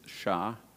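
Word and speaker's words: Shittim: But I actually looked it up, and the correct pronunciation of that city's name is Shitim.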